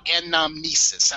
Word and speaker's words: Anamnesis: And it's called Anamnesis. I believe that's how it's pronounced. – Yeah, there you go. – Okay, A-N-A.